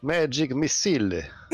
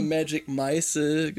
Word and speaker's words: Magic Missile: Es ist immer Magic Meißel gewesen.